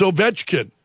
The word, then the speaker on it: Ovechkin: I may pronounce this name, forgive me if I do, Alex Ovechkin. It's Ovechkin.